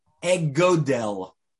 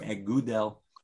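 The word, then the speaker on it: egodel (אגודל): The Hebrew word for thumb is egodel. Egodel. If you want to know in Hebrew, and it's important why I'm going to spell it for you, is Aleph, Gimel, Vav, Dalid, Lamed. Egodel. Egodel or egudel.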